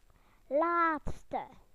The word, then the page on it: last: British English